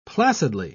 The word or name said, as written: placidly